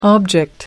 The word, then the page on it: object: American English